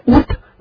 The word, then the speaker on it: Août: Tiếng Pháp đọc là Juillet với lại August với lại Août, nghe rất là khác. Juillet với Août nghe khó nhận ra.